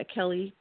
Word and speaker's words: Kelli: My name is spelled with an I, Kelly Prather, K-E-L-L-I, Prather, P-R-A-T-H-E-R.